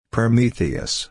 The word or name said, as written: Prometheus